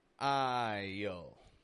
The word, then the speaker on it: isle: I-S-L-E. Drop the S right out of there and kind of replace it with a Y sound. I-yo.